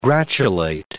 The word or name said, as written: gratulate